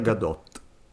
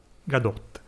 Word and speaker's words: Gadot: Si, si pronuncia è... Gadot. – Gadot.